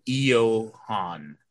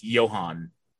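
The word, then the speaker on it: Ioan: I've heard him pronounce it Johan. So it's Johan.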